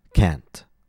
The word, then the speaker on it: can't: But to express the negative, C-A-N apostrophe T, we stress the modal verb, can't. Finally, let's discuss the pronunciation of C-A-N-T with regards to the T sound. As I mentioned before, Americans, native speakers, when they're speaking, they usually don't pronounce the T. That's because in American English, if a T comes before a consonant, an American will often replace the aspirated T, t, with what's called a glottal stop.